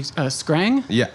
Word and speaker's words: Skrang: And you work here in Kuching at, am I pronouncing it correctly, Skrang? – Yeah.